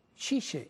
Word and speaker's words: Tite: No, pero bueno, hablando de Neymar, sí, déjame explicarte de que ya Xixe, a pesar, ajá, de que se escribe Tite, creo que se pronuncia Xixe, sí, el técnico de Brasil, acaba de empezar a convocar jugadores para la Copa América del 2019.